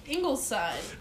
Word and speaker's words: Ingleside: English side? – I mean, how do you pronounce that bullshit? – Ingleside. – Ingleside district? Ingleside. What type of bullshit name is that for a city?